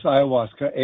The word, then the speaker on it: ayahuasca: Is it ayahuasca? – Yes, you are. It's ayahuasca, A-Y-A-H-U-A-S-C-A, ayahuasca.